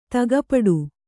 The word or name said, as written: ದುಃಖಪಡು